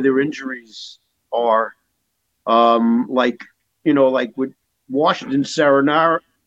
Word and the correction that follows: Samsonov: Oh, Samsonov? – Samsonov.